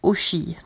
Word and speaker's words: Hoshi: une nouvelle artiste musicale qui s'appelle Oshi. Donc ça s'écrit H-O S-H-I et euh,